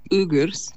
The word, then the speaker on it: Uyghurs: Yes, yeah, so it's actually pronounced Uyghurs.